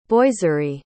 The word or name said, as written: boiserie